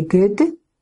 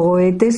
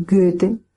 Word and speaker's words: Goethe: Cada vez que lo digo y tengo un alemán parlante o una persona que pronuncie muy bien el alemán, me corrige y hace una especie de Goethe. Bueno, pues este Goethe para nosotros, o Goethe si lo queréis pronunciar a la española, y Goethe para los que lo dicen mejor o más en la forma más apropiada.